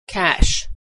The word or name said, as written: cash